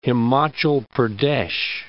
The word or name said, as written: Himachal Pradesh